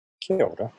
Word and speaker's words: kia ora: Kia ora. – Yeah. Can you explain to everyone what kia ora means? – Yeah. First of all, I love the way you say it. Beautiful pronunciation.